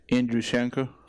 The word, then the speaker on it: Andruschenko: Andruschenko.